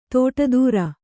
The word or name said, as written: ತೋಟದೂರ